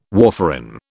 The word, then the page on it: warfarin: British English